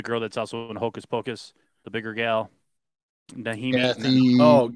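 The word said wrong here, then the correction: Najimy: Nahimi or whatever. Najimi.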